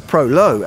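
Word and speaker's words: Prolog: While Common Man is taking tips from Australia and following the needs of a younger customer, Prologue Coffee Bar, or Prolo as it's pronounced in its native Danish, has been looking to Central America.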